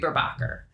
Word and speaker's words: Burbacher: I married it and they pronounce it Burbacher. I think Burbacher. I think it should be Burbacher.